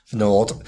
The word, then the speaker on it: Fnord: Fnord. Fnordcon. – Fnordcon. – Fnord. Like a fjord, but Fnordia.